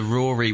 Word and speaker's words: Ruaridh: Or H-O-D-R-I? – Is it R? I think that's Rodri. There's a Rory, which is like R-U-A-R-I-D-H, which is very confusing. – Oh, but, yeah, and there's a Rory in Irish, which is R-U-R-R-I-A-I-F-O-D-A-I, accent on the I-A-I-A-I-R-I.